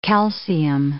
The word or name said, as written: calcium